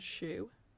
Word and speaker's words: Sheux: Are you familiar with the Twitter account Jeans and Shoe? – No. – Shoe is spelled S-H-E-U-X.